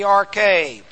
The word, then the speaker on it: arche: Now here is the arche. A-R-C-H, long E.